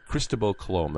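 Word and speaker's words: Cristóbal Colón: Cristobal Colom, that's the proper way to pronounce his name.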